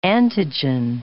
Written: antigen

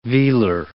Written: velar

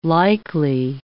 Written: likely

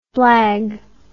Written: blagger

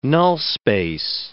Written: null-space

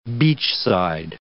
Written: beachside

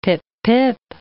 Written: pip-pip

